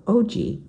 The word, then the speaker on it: Ogee: The owners are Chef Matt and Monique Breen, whose bistro, OG, is next door. That's spelled O-G-E-E.